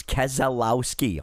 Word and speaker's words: Keselowski: Brian confirms Keselowski, all four syllables.